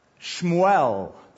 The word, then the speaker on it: Shmuel: Verse 20, it came to pass in the process of time that Hannah conceived and bore a son and called his name Shmuel. That's the Hebrew pronunciation. Samuel, as we say it, saying, because I have asked for him from the Lord.